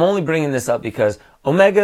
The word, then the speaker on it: Omega: So Omega has the long O sound. Omicron has the short O sound.